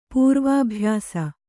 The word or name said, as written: ಪೂರ್ವಾಭ್ಯಾಸ